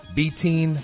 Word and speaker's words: betaine: Betaine. What's the right way to pronounce it? – You know, I pronounce it betaine.